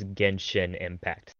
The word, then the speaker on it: Genshin Impact: Just the pronunciation of it, it's Genshin Impact.